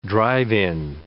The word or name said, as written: drive-in